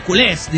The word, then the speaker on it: culés: Y de ahí lo de culés, dice, con el acento en la E. Dice, en catalán, lógico.